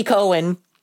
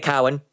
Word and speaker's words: Cohen: So Andy Cohen... I was about to say Cowan.